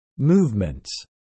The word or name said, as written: movements